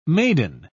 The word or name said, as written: maiden